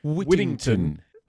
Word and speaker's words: Whittington: Just pronounce the T. So Whittington. – Whittington. – You can say that in an American accent. Whittington. You turn the T's into D's.